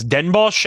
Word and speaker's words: Den Bosch: So starting off with Den Bosch, by the way, just for reference, I actually looked up how to pronounce the full pronunciation, but apparently people refer to the actual city as Den Bosch, as a bit of a shorter way of saying it.